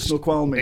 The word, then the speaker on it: Snoqualmie: Well, I've been talking to our chief coach across there in Dirtfish HQ in Snoqualmie. Is that how you say it? Snoqualmie.